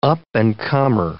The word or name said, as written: up-and-comer